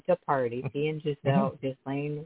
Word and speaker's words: Ghislaine: Ghislaine. We don't pronounce all the letters, yes.